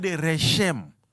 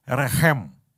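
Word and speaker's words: rechem (רחם): reshem. Okay? But it's pronounced in Hebrew as rechem.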